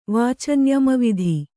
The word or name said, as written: ವಾಚಂಯಮವಿಧಿ